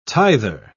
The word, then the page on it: tither: British English